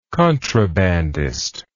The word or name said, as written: contrabandism